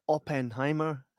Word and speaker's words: Oppenheimer: It's Oppenheimer.